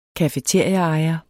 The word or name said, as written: cafeteriaejer